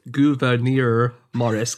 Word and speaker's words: Gouverneur: Okay, so basically, first of all, Governor Morris, you might know him if you've never heard his name said as Gouverneur Morris, because that's how his first name is pronounced.